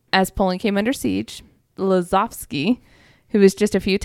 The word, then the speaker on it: Lazowski: Eugene Lazovsky. I'm going to pronounce some things wrong here, but I think that's right. Lavosky, because they're W's or V's, right? – Yes.